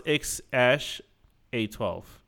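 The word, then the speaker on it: X Æ A-12: Because that AE is pronounced as Ash. – Ash. – So it's, yeah, it's pronounced X Ash A12.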